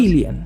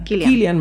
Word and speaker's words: Cillian: No sé si voy a pronunciar bien su nombre, pero el, el actor, el protagonista, Cillian, se, pronuncia. – Killian. Killian. Murphy. Parece que él, él, dice que así se pronuncia su nombre.